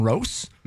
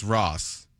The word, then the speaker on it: Ross: Are we mispronouncing it? Is his name Rose? – No, it's Ross.